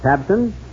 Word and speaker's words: Tabcin: But have you heard about Tabsin? That's spelled T-A-B-C-I-N.